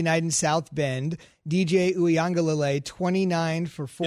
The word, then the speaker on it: Uiagalelei: So you are in Uiagalelei.